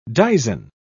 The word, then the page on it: dizen: British English